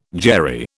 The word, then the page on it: Jewry: British English